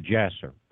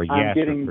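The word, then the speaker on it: Jasher: Well, have you read Jasser? Or Yasser, pronounced properly? – No, I've got parts of it.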